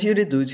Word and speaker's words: Yuri Dud: Uh, so it was in a conversation with, I hope I pronounced the name correctly, Yuri Dud. Is that? Yes, his name is Yuri Dud.